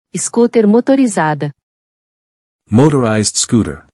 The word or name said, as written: Scooter motorizada